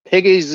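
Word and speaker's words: Pegues: And I am presuming it is pronounced Piggies because there is a creek near the area I live in that is pronounced Piggies. And that's how I've always pronounced it. So I'm assuming it is the same.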